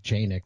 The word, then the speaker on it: Canik: Canik. I know it's supposed to be pronounced like Janik, but, yeah, but everybody I know calls it Canik.